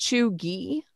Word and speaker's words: Cheugy: So it's Cheugy. It's pronounced Chuggy.